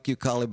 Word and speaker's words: Youkali: And it's spelled Y-O-U-K-A-L-I, like you, and then Kali, that the goddess.